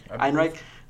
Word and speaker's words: Ehrenreich: How you say his name? – Einreich.